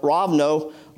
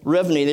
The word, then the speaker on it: Rivne: We flew into Kiev and then took a five-hour bus ride to the small town of Rovno, Rivne, they pronounce it two different ways, and served there handing out the Book of Hope, a ministry of missionary Bob Hoskins.